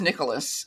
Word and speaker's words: Nikolis: Actually, my married name is Nicholas, but it's spelled N-I-K-O-L-I-S.